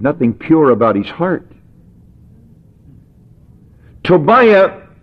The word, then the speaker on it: Tobiah: Tobiah, or in its harsher Judean pronunciation, Tobijah.